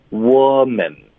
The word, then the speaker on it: woman: 可是它的发音是不一样的，所以针对单数的，它的发音是 wo。是一个的音 woman，然后复数的它的发音是 we 的音 women，